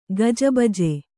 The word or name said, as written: ಗಜಬಜೆ